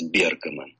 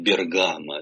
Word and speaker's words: Бергамо: Он родился в 1908 году в очень бедной семье, в семье башмачника из Бергамо. Ну, я ударение ставлю по-итальянски. Мы привыкли вслед за очень популярным фильмом «Водевилем» говорить «Бергамо». И мне как-то неловко поправлять наших соотечественников.